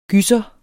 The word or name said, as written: GYSSERNE